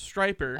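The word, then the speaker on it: Stryper: Is it Striper or Stripper? – I've heard the name. – I think it is. It's S-T-R-Y-P-E-R. – Oh, okay. – So I'm pretty sure it's pronounced Striper.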